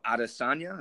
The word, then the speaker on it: Adesanya: Adesanya. Am I saying his name right? I hope so. – Mm-hmm. Yeah. – Cool. I thought so.